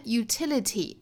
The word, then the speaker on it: utility: 我们来看 utility，它的重读音节是在 t utility 哒哒哒哒，后面 accessory 哒哒哒哒哒哒哒哒，在后面的 iconography 哒哒哒哒哒，nography。